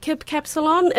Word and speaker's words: Kip Capsalon: talk to us about Kip Capsalon. Am I saying that correct? – Yes, you are saying that right.